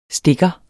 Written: stikker

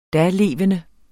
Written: dalevende

